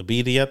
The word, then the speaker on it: birria: I don't pronounce them correctly. The birria. – The birria tacos? – Yeah, there you go. Perfect.